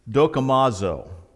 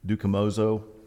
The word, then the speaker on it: dokimazo: This word here, test, in the Greek word, it is the word dokimazo. Now, I may butcher those. I'm not Greek, but I'm trying to pronounce it the way it says. It's D-O-K-I-M-A-Z-O. Dokimazo, dukimozo, whatever.